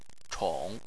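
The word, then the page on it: chong: tone 3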